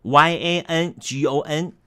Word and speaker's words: Yangon: Y A N G O N，读音上面从 Rangoon 变成了 Yangon，从这读音的大大不同。